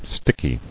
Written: Stickiness

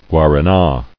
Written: Guarana

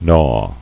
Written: Gnawer

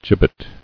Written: Gibbeted

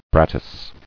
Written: Brattice

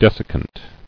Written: Desiccant

